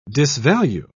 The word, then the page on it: disvalue: British English